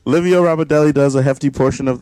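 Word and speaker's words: Ramondelli: I'm like, is it Ramadelli? I don't want to say his name wrong. Hold on. – Right. Yeah, it's Ramadelli. – That's how you say it? – Yeah.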